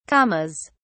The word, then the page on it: commas: British English